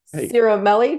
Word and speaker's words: Cirimele: So Jason Cirimele just joined us.